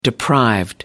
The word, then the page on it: deprived: American English